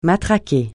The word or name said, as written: matraquer